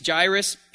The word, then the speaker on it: Jairus: I had to put Jairus as it phonetically sounds, because I've been saying Jairus for a long time. So anyway, it's Jairus, and so he's a ruler of the synagogue.